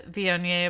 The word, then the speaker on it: Viognier: So I am drinking a Viognier, which I did have to Google how to say that because it's spelled V-I-O-G-N-I-E-R, but very explicitly said on the internet, Viognier. So that's how I'm remembering it.